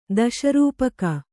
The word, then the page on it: ದಶರೂಪಕ: Kannada